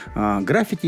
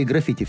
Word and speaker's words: граффити: Под этим авторы граффити или граффити? Все время путаю, Вик, это не помнишь? – Двойное ударение допустимо.